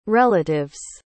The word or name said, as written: relatives